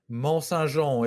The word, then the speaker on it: Monsaingeon: Monsaingeon. It's French.